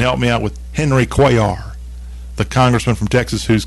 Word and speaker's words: Cuellar: This congressman, his last name is pronounced Cuellar. Henry, I may have said something completely wrong. Henry Cuellar.